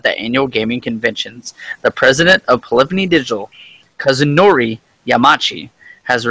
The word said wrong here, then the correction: Kazunori Yamauchi: I know how to pronounce his name. – Do it. Let me hear you. – Kazunori Yamauchi.